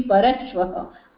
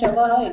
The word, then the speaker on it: परश्वः: It should be parashvaha. Why? Because shavaha means dead body. So if you pronounce it as shavaha, it becomes wrong.